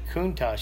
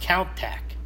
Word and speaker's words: Countach: And when I was over there, I'd seen one, and I said, Countach. He says, that sounds more sexy or whatever you want to say when you say Countach. He said, but it's Countach. – Oh. – He says pronounced Countach.